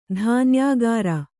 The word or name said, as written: ಧಾನ್ಯಾಗುರು